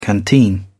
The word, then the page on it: canteen: British English